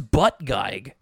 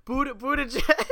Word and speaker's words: Buttigieg: How do you say it? – Buttigieg. – Buttigieg. Okay. – Buttigieg or Buttigieg. It is most certainly not Pete Buttgeig.